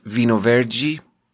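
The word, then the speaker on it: vinho verde: But then Michael Herringhouse taught me, because he speaks Portuguese, that vino verde, which is a Portuguese, well, it's obviously, it means green wine. It's a type of what we would classify as white wine, but it's a type of white wine that has a light green color. And it's actually pronounced vino verde.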